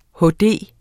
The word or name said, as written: HD